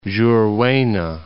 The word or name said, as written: Juruena